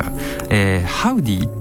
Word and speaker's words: HOVVDY: ハウディっていう HOVVDY って書いてハウディって読むみたいなんですけど、またねこのタイトルがいいんですよ、トゥルーラブっていうね、もう日本人にぴったりのタイトルですよね。